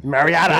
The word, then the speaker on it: Mariota: Is that how you say it? – That's what I say. All right. It's like the Marriott Hotel. God dang it. Ah, Mariota.